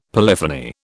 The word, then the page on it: polyphony: British English